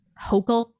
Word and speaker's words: Hochul: I think it's Hochul. – Hochul? – Yeah.